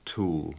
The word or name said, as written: tool